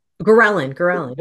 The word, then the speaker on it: ghrelin: So even the mechanisms itself, it's a, and I'm gonna butcher the word here, ghrelin, I believe is how you pronounce it, the hormone. – Ghrelin, yeah. – Ghrelin, yes, that one.